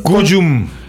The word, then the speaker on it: Cullum: Y con Cullum. Así con doble L. – C-U-L-L-U-M.